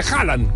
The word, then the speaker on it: Haaland: No se dice Holland, se dice Haaland.